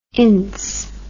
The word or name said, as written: Ince